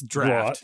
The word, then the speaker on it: draught: That's draft. Draught. That's draft. That's draught. That's draft. You go look that up. You go look at that pronunciation. That's draft. It's the stupidest way to pronounce to spell draft in the world, and Lennon did it. It's draft.